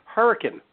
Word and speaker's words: Hurricane: I'm going to pronounce it right for you, Hurricane. – You did say it right.